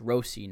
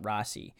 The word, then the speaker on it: Rossi: Marco came out and said my last name's actually pronounced Rossi, not Rossi.